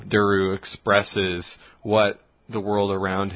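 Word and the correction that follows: Dersu: So, Dursu, I think is his name. – I'm sorry, Dursu. – Yeah. – I'm mispronouncing it.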